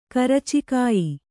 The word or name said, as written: ಕರಚಿಕಾಯಿ